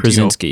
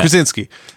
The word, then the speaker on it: Krasinski: Everyone is familiar with John Krasinski, I think it's pronounced. And Krasinski, you know, yeah. Krasinski.